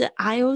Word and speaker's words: aisle: 好，接下来是 aisle seat，这个也是在我们的这个飞机上面会用到的 aisle，就是走道，不要念成 aisle，那个 s 在这边呢是没有发音的，很多 is 的字，这个字它也是。